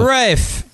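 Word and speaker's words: Ralph: I don't like the way he pronounces his first name, but I'll deal with Rafe.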